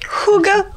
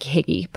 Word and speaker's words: hygge: Hygge. – Hygge, yes. – Hygge. Oh. So side note, this word looks like higgy, but it's pronounced hygge.